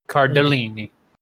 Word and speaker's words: Cardellini: And I'm probably going to say this wrong. Linda, who plays Judy Hale. Linda Cardellini. – Cardellini. – Yeah, Linda Cardellini. – You gotta get your fingers. – Cardellini. There we go.